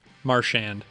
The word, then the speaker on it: Marchand: Marchand.